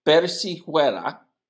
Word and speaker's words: persiguiera: Persiguiera. Persiguera. – No, no. – Persiguera. – No, no es persiguera, es persiguiera. – Oh. – Porque la G-U-I tiene pronunciación de gui. Persiguiera.